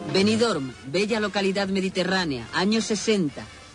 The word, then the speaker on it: Benidorm: Benidorm, hay que pronunciar la M si no, no vale, era el sueño de una noche de verano.